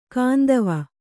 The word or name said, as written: ಕಾಂದವ